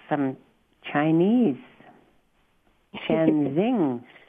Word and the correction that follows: Sheng Zhen: How do you pronounce that? – Sheng Zhen. – Yeah, Zhen teaching. – Sheng Zhen. – Yeah, Sheng Zhen.